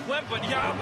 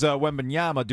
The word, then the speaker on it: Wembanyama: Do we get a proper pronunciation on this? Hit a 3-pointer last night, apparently. Here we go with the call. – Step back. Wembanyama. Oh, this kid is good. – So that was Wembanyama.